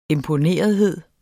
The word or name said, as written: imponerethed